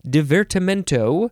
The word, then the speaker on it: Divertimento: It is the number one definition for divertimento.